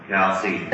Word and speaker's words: Chalcedony: The question is with regard to chalcedony. What is it and what are we trying to do? The name chalcedony, which can also be pronounced chalcedon or chalcedon,